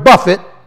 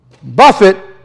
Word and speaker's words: buffet: Broken down into its literal root, it means to strike repeatedly, the word buffet, or act as an active hostile opponent, antagonist, in the manner of a bare-knuckle boxer, pugilist. That's what this word is. Buffet is a verb. It's not buffet. It's spelled like buffet, B-U-F-F-E-T.